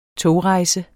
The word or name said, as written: togrejsen